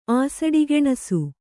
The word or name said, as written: ಆಸಡಿಗೆಣಸು